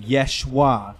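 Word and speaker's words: Yeshua (ישוע): And so Yeshu is obviously is actually meaning Yeshua, which is the Hebrew way of pronouncing Jesus.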